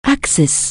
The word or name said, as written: Axis